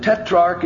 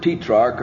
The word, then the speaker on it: tetrarch: I've been saying tetrarch, and I wanted to make sure, and tetrarch and tetrarch are both accepted pronunciations. So take your choice, Herod the tetrarch or tetrarch, and Saul.